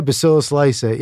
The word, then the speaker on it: bacillus lysate: And you think it's because of the bacillus lysate? Did I say that right? – Yeah, bacillus lysate.